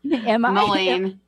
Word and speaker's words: Moline: She teaches astrology and tarot at Blackhawk College, Moline, and I hope I'm saying that right. – Moline, yeah. – Moline, okay, Illinois.